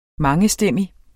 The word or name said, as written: mangestemmig